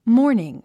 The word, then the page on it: morning: mɔ’ː r niŋ; モーニン